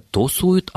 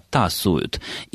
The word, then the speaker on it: тасуют: Ну, прежде всего, Александр Анатольевич, все-таки карты не тосуют, а тасуют.